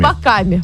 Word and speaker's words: альпака: Альпака на последнее А ударение.